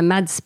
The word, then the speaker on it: Mads: bon, ben, on voit des hommes euh, qui, ben, surtout le, personnage de se dire Mads, parce que nous, on prononce quand même euh, le scandinave, le S.